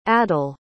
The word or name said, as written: adil